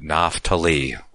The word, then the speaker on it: Naphtali: So that's how we would pronounce it, Naphtali, with a F. According, the PH would be pronounced as an F, that is.